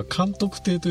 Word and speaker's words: 監督亭: ちなみに、はい、あの、話から飛んじゃいましたが、さっきのは監督亭と読むみたいですね。